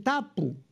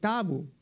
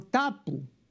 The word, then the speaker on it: tapu: Pero el capitán Cook pronunciaba debidamente, decía tabú, en Polinesia dicen tapu, no dicen tabu, sino tapu.